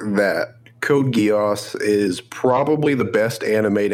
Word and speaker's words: Code Geass: About Code Geass.